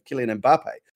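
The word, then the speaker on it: Mbappé: By the way, as an aside, Mike, congratulations on the correct pronunciation of Kylian Mbappe.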